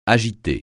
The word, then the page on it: agiter: French